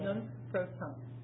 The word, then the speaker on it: nunc pro tunc: Nunc pro tunc.